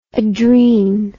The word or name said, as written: adren